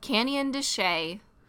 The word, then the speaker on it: Canyon de Chelly: Here's our number five, is often mispronounced by us before we went there. It is called Canyon de Chelly. – Spelled Canyon de Chelly, basically.